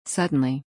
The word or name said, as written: Suddleny